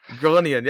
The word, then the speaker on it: Groningen: Groningen, yes.